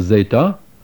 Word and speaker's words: zeta: These are the letter zeta, often called zeta, pronounced zd, as the letters sd are pronounced in the English word wisdom, zd.